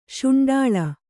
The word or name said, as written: ಸುಂಡಲು